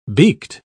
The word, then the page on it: beaked: British English